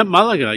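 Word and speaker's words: Málaga: Comic-Con, I'm not sure if I pronounced it correctly, Malaga? – Yeah, Malaga. Yep.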